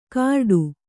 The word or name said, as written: ಕರಡು